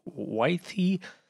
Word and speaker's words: Waithe: W-A-I-T-H-E. Wythe.